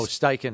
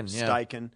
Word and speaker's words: Steichen: Shane, Eagles offensive coordinator Shane, I don't know how you say it. Oh, Steichen. – Steichen. Yeah.